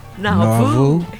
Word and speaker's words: Nauvoo: The Jades did Walking All Alone on the, I don't know how to pronounce this label, it's N-A-U-V-O-O. – Nauvoo? – Yeah.